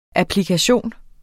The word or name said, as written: applikation